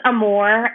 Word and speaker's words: Armour: Pronounced Armour. Husband is a New Orleanian, so his French Creole last name pronunciation, but Armour just depends on where you come from, how you pronounce it.